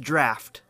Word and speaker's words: draught: We discovered that the word D-R-A-U-G-H-T is pronounced draft.